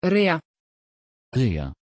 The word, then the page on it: rea: Dutch